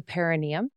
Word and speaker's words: perineum: I'd love to move to the perineum. Is that how you say it? – Yes, that's great.